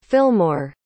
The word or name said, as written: fillmore